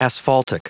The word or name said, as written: asphaltic